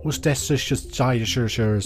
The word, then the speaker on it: Worcestershire: Worcestershire sauce.